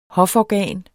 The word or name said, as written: hoforgan